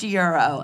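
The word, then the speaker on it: gyro: It's Euro. It's lamb meat. – It's pronounced Euro.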